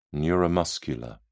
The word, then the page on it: neuromuscular: British English